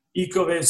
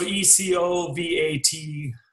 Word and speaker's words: Ecovative: Ecovative, so Ecovative, T-I-V.